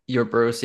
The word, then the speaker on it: Ouroboros: I don't know how to say it, but Ouroboros. – Yeah, Ouroboros, yeah.